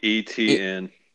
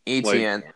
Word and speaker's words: Etienne: I know he doesn't have like the stats that, and I'm not, as it Etienne, how do you say it? JV? – Etienne. – Etienne.